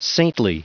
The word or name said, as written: saintly